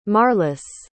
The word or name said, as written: marlys